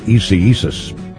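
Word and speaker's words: Isaias: It's spelled I-S-A-I-S, but you're supposed to call it Eseesus.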